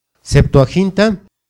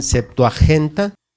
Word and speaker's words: Septuaginta: Septuaginta. A veces algunos la pronuncian septuagenta,